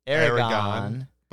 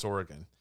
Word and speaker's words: Oregon: Is it Oregon or Aragon? – Well, they say Aragon, but it's pronounced Oregon. They're New York City guys, like Donald Fagen and Walter Becker. Actually, upstate New York guys by origin, okay, but you know, they met each other in New York City, and so it's I think it's really just sort of their pronunciation.